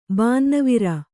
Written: ಬಾನ್ನವಿರ

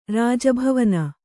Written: ರಾಜಭವನ

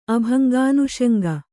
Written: ಅಭಂಗಾನುಷಂಗ

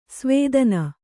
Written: ಸುವಿಧಾನ

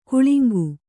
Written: ಕಳಂಕ

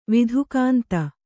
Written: ವಿಧುಕಾಂತ

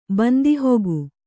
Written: ಬಂದಿಹೊಗು

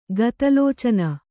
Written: ಗತಲೋಚನ

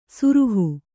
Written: ಸುರುಹು

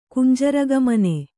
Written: ಕುಂಜರಗಾಮಿನಿ

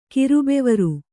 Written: ಕಿರುಬೆವರು

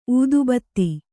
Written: ಊದಬತ್ತಿ

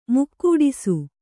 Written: ಮೈಗೂಡಿಸು

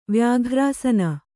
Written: ವ್ಯಾಘ್ರಾಸನ

